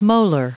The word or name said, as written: molar